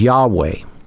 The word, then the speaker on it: Yahweh: No scholar believes that's the case, because there are root words in the Hebrew that would give us a better guide as to how that would to be pronounced. And so the word, that the other alternative name of God based on the Hebrew that is generally accepted is Yahweh.